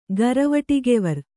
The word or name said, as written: ಗರವಟಿಗೆವರ್